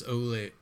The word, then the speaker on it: Olave: I'm looking at this CBS mock draft from today, and whoever this dude is giving us Chris Olave.